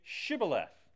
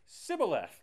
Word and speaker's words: Shibboleth: And Jephthah would say, "Say Shibboleth." And they would say, "Sibboleth," because they couldn't pronounce the SH.